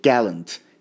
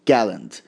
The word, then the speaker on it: gallant: این سه تا در واقع تلفظ برای این واژه هستش، ولی بیشتر gallant هستش که خیلی بیشتر از همه استفاده میشه، یعنی استرس روی جی باشه. gallant، gallant، gallant، gallant.